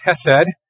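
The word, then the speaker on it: hesed (חֶסֶד): It's written in Hebrew there, not that any of us read Hebrew, but the word is pronounced hesed, and it speaks to us of God's unfailing love.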